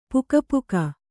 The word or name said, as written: ಪುಕಪುಕ